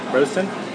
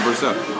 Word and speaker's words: Brosseau: Brosson? – Brosseau.